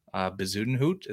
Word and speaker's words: Bezuidenhout: If I was going to take a guy that I think is maybe it's a poor man's Fitzpatrick, is maybe Bezuidenhout. Is that how you say it? – Mm-hmm.